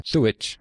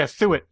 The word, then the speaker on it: Thuot: Uh, Pierre Thuot, I think is how you say the last name, Thuot. I've heard it enough times. Oh yeah, Thuot.